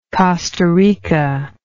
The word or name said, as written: costa rican